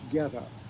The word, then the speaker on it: goetta: I'll spell it G-O-E-T-T-A, and it's pronounced Getta. Like Getta Life or...